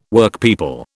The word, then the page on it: Workpeople: British English